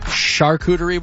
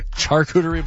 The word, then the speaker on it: charcuterie: I guess it's a charcuterie board. – It is? Okay. – Instead of a charcuterie board.